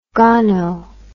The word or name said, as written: gono